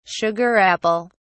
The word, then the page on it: sugar apple: British English